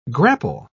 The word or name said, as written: grapple